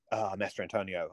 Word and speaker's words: Mastrantonio: Every Cameron movie, like, I mean, even The Abyss is so totally a love story between Ed Harris and Mary Elizabeth Mastrantonio.